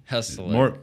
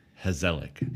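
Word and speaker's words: gezellig: Hezelig. Nope, not a hard G. – Hezelig. – More of an H. Hezelig.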